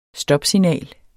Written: stopsignal

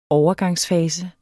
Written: overgangsfase

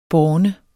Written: borne

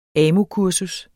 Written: AMU-kursus

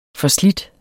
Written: FORSLIDTE

